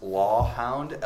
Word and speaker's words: LawHound: pronounced LawHound, L-A-W-H-O-U-N-D.